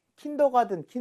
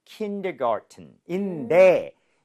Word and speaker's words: kindergarten: kindergarten인데, 이게 현실적으로는요, garden 발음할 때, T 사운드를 거의 안 내요. – 아, 그래요? – 네, 그래서 kindergarten처럼 은처럼 발음하는 경우가 굉장히 많습니다.